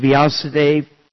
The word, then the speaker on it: Vyasadev: With the hope of meeting Srila, as Prabhupada calls Vasudev, Vyasadev. I like Prabhupada's pronunciation, so I'm taking that.